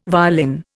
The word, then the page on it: violin: Indian